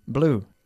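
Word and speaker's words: blew: Blue. Es que se pronuncia igual, igual, igual que el color.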